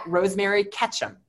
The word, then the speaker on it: Rosemary Ketchum: So everyone is at Rosemary Ketchum. – Spell it, we're blind. – R-O-S-E-M-A-R-Y-K-E-T-C-H-U-M.